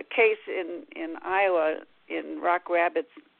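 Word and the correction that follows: Rock Rapids: uh, Rabbit, I'm not pronouncing that right, Rapids, uh, Iowa, uh, of a young woman who was uh, found and buried as a Jane Doe in 1978.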